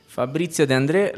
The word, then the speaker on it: Fabrizio De André: Fabrizio De André, la canzone di Marinella.